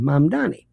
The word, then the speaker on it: Mamdani: Think mom and dad, Donnie Osmond, Mamdani.